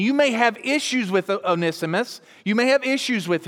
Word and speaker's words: Onesimus: you can pronounce it in many different ways. I pronounce it Onesimus.